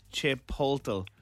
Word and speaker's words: Chipotle: Someone said, as a Spanish-speaking Latin American, I can honestly say it's pronounced Chipotle. There is no silent letter or anything weird.